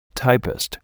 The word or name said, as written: typist